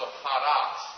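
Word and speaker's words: hados (ὁδός): O-D-O-S. That's the word there. And we would actually pronounce it probably with an H sound. Well, certainly with an H sound at the beginning because of the little mark that's over the O there. And so hados, like that.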